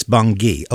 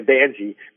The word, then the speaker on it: Bangi: Okay, thanks for straightening me out on that, because I pronounced it Bonji. It's Bonji. Okay. – Well, when I first started, I was saying Banji because it's spelled B-A-N-G-I.